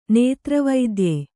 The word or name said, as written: ನೇತ್ರವೈದ್ಯೆ